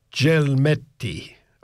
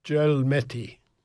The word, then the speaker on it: Gelmetti: And it's being performed by the Sydney Symphony Orchestra, conducted by Gianluigi Gelmetti. I presume that's pronounced, I think G-E, if it's followed, G followed by an E in Italian is J, like a J, with an H or any other, like A or O or U, it's G.